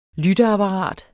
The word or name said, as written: lytteapparat